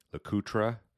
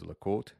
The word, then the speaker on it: LeCoultre: And then the last part, you know, if you talk to old collectors, they'll call it LeCoultre, but really today most people pronounce it like the French would, which is LeCoultre. So that last bullet really is the correct pronunciation from a French-speaking side, Jaeger-LeCoultre.